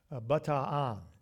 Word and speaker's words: Bataan: In World War II, there was known a death march, and I had to look up how to pronounce it. Bataan is the pronunciation.